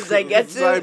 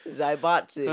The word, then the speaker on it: zaibatsu: Zaigatsu? – Zaibatsu. – Zaibatsu. – Zaigatsu. Or Kagame, whatever you want. – The blood Zaibatsu. – Zaibatsu. – Zaibatsu. – Zaibetsu. – Zaibatsu.